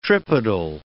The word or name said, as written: tripodal